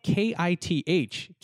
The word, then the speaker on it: Kith: it's spelt K I T H kith